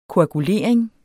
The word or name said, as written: koagulering